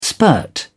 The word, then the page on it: spurt: British English